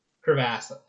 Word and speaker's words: crevasse: Yeah, it's called Giant Crevice. Crevasse, I think, technically, from the way it's spelled.